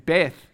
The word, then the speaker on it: Beth: So what's Aleph is their first A, and Beth, and we're going to read a little passage from Beth, or Beth. Is it Beth or Beth? It's spelt B-E-T-H, but I think it's Bet.